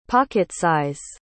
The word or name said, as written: pocket-size